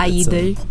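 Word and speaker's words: (G)I-dle: In case you, our listeners, weren't already aware, the video game developer Riot Games tapped Soyeon and Minyeon of G-Idol, or I-Idol, however you say it in Korean. I know the G is silent. – I-Idol.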